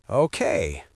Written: okay

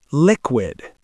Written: liquid

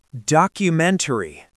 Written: documentary

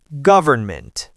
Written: government

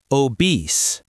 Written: obese

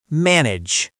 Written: manage

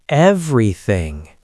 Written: everything

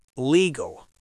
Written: legal

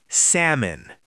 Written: salmon

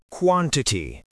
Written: quantity

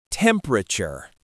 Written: temperature

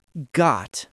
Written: got

